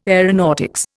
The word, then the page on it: aeronautics: Indian